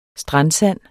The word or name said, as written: strandsand